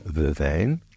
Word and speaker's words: verveine: Verbena in French is called either vervain or vervain. Vervain is spelt V-E-R-V-E-I-N-E, and vervain is V-E-R-V-A-I-N.